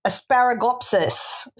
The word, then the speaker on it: Asparagopsis: I do have to check the pronunciation. It's sort of a bit like asparagus. It is Asparagopsis seaweed.